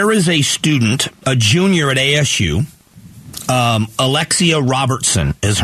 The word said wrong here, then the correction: Roberson: I think it's pronounced Roberson. There's no T in there.